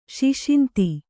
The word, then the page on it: ಸುಸಂಧಿ: susandhi